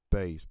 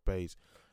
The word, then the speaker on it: Buys: It was pronounced by one of the commentators, it was pronounced Bays, but her name is spelled B-U-Y-S, so I'm assuming that U is spelled like pronounced almost like an A.